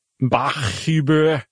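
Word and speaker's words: Bachhuber: So this dude, Kevin, how would you pronounce that? – Bach-Huber. – B-A-C-H, which is fine. That's clearly Bach. And H-U-B-R, you just don't often see two H's side by side.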